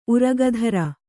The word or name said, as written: ಉರಗಧರ